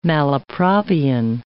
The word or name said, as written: malapropian